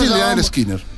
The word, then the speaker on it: Lynyrd Skynyrd: Se dice Lynyrd Skynyrd.